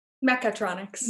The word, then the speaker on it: Mechatronics: Metatronics? – Mechatronics. – Mechatronics. Yeah, I said that wrong up there.